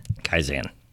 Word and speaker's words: Kaizen: Kaizen.